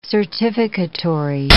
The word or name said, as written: certificatory